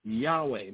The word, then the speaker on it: Yahweh: It is Yahweh, which is pronounced Yahweh, meaning he who is or he who brings, the self-existing one, Jehovah or Yehovah.